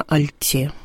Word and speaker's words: альте: Действительно, надо говорить на «альте». «Альтовый». альтом.